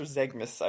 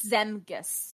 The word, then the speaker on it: Zemgus: Is it Zemgus or Zegmus? I always forget. It's always no, it's Zemgus.